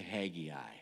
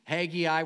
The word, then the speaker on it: Haggai: Everybody say Haggai. Haggai. Some pronounce it a little bit different. Haggai.